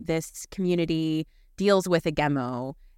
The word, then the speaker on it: Gemo: So you're in the forest, and there's this, the hemo, I don't know how you pronounce it. This demon or this sort of spiritual. – How do you spell it? – G-E-M-O.